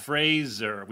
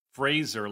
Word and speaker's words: Fraser: It's what? Fraser. We've both been saying Fraser. – It's Fraser? – Fraser, like Fraser. – Like Laser. – Like Laser. Fraser.